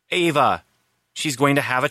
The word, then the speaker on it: Eva: Ava, spelled E-V-A, but pronounced Ava, and Christine, spelled C-H-R-I-S-T.